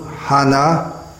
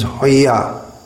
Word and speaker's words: hana: Notice that the Hana becomes Hoya.